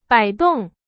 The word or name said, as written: 摆动